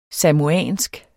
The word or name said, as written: samoansk